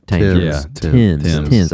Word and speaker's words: Thames: Thames River? Thames. I know I wasn't saying that right.